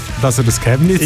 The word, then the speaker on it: Chemnitz: Chemnitz, ja.